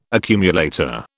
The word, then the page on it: accumulator: British English